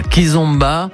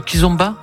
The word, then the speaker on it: kizomba: Salsa, bachata, kizomba. Je le dis bien, oui, kizomba.